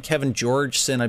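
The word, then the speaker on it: Kevin Jorgeson: All right, so you've got these guys, Tommy Caldwell and Kevin Georgeson, I believe I'm saying that right. – Yeah.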